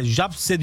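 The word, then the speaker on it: Zabrze: ön eleme turu ilk maçında Polonya'nın Gornik takımını Gornik Zabirse, Japse diye okunuyor.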